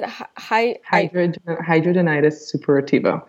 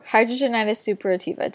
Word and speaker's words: hidradenitis suppurativa: Hidradenitis suppurativa. – Hidradenitis suppurativa. Did I say that right? – Yes.